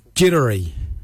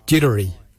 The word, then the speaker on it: djerdari: Jardak is spelled D-J-A-R-D-A-G, Jardak, grey. We'll now look at the word for brown, Jittery. Jittery is spelled D-J-E-R-D-A-R-I, Jittery, brown, Jittery.